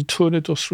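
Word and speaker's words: tournedos: I mean, one famous use of Madeira, of course, is in Torna dos Rossini, or Torna do Rossini, depending on how you like to pronounce it,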